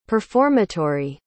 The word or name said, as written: performatory